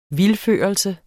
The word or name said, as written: vildførelse